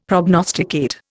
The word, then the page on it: prognosticate: Indian